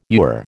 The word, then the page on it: ewer: American English